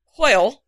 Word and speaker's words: hwyl: You want to do it for, and he used a Welsh term that we had a lot of fun with, the hoyl, I think is how it's pronounced. It's actually spelled H-W-Y-L. Gotta love the Welsh. They don't like vowels.